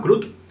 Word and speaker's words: Crude: Se llama Crut. Es un juego que fue editado originalmente en 1974. – Que se escribe Crude.